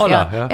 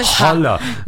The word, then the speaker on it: hola: Olla, ja. Das Haar spricht man nicht. Viele sagen Holla.